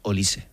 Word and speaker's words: Olise: Es Olise. Olise. – indudable que el galáctico, no por actitud, pero sí por juego, que quiere el Real Madrid, sin ninguna duda es Olise.